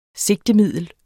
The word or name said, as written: sigtemiddel